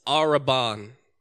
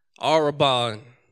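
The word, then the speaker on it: Araban (ἀρραβών): I know how to pronounce this one. It's Araban. Araban.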